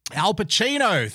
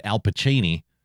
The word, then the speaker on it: Al Pacino: She actually said Al Pacini, which sounds like a kind of pasta recipe. But yes, Al Pacino. Of course it was Al Pacino.